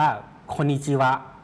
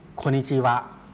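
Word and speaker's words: โ คนิ จิ วะ (こんにちは): สำนวนนี้ออกเสียงว่าโคนิจิวะครับโคนิจิวะ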